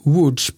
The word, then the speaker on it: Łódź: Jag har varit i en stad som heter Łódź, som stavas med våra bokstäver L-O-D-Z. Alltså vi säger Lodz, men det uttalas Łódź på grund av att bokstäverna är inte svenska bokstäver. De har lite streck och accenter och sådär som gör att man uttalar dem inte alls så som vi tror.